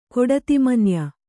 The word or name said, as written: ಕೊಡತಿಮನ್ಯ